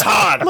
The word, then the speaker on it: Han: It's Han. – Like, it's like, it's like hand, but without the D.